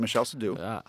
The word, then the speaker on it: Michel Seydoux: So how did Jodorowsky and his producer, Michel Seydoux, am I saying that right? Michel Seydoux? – Yes, correct. Yeah. Michel Seydoux.